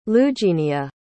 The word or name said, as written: lugenia